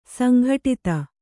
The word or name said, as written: ಸಂಗಡಿತ